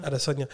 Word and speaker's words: Adesanya: Adesanya? – Adesanya.